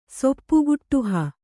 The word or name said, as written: ಸೊಪ್ಪುಗುಟ್ಟುಹ